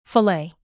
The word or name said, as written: fillet